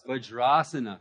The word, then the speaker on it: Vajrasana: Vajrasana. Very good. See? You're learning. So that would become a long A. That would become a long A there. Vajrasana.